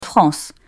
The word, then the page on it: France: French